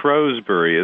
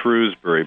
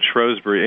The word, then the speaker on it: Shrewsbury: But I had a trip to Britain in February and spoke at the, in Shrewsbury, as the British pronounce it. We would say Shrewsbury, but Shrewsbury, England, where Darwin was born.